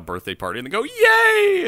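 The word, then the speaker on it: Ye: it's just Y E pronounced Yay, like Kanye, but just Yay.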